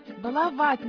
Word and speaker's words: баловать: Нет, баловать надо говорить. А дедушка тебя избаловал. – Правильно сказать нужно. Баловать, баловаться. Избалованный, балуешься. – По правилам русского произношения надо говорить: «Я балуюсь», «Он балуется», «Мы балуемся».